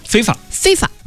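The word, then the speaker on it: FIFA: 昨天呢，这个 FIM 这应该怎么念？ – 非法、哎、啊，应该这么就是专业术语，非法。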